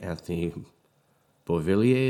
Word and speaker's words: Anthony Beauvillier: So when we're breaking things down here, we see Bo goes to the New York Islanders for Atu Ratti, Anthony Beauvillier is the right way to pronounce it, and a protected 2023 first-round draft pick.